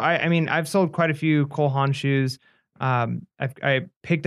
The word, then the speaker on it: Cole Haan: No, Cole Haan, you're right.